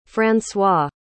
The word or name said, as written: francois